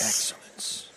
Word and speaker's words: excellence: Excellence. – How do you say excellence in French? – Excellence. – You just add an accent to it.